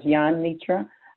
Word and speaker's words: Yanitra: All right, the J is pronounced like a Y, so it's Yanitra Waddell,